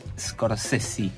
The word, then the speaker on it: Scorsese: Nos vamos a centrar en el ciclo de Scorsese, de Martin Scorsese, como se debe pronunciar.